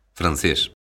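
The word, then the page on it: francés: Galician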